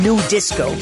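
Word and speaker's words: Nu Disco: Y déjame decirles que dentro de este género del house francés nace un subgénero que es el New Disco, que se escribe N-U Disco,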